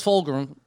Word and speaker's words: Fulgham: If I can't pronounce Travis Fulgham... – He did it again. There's no R in it. There's no R in his name. Sorry, Fulgham. – Like Frodo. Fulgham. Travis Fulgham.